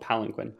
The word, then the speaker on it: palanquin: That's not how to pronounce it, but it's spelled palanquin.